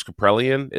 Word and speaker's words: Kaprielian: The biggest discrepancy right now in terms of performance is the Friday night matchup, which is Senga versus James Kaprielian.